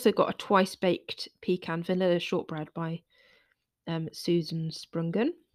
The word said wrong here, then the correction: Spungen: Sorry, am I saying that wrong? It's Spungen, sorry.